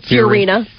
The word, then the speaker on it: Fiorina: Fiorina.